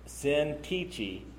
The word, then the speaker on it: Syntyche: So now I appeal to you, Yodia, or Yodai, you can see it there, and Syntyche is how that's pronounced.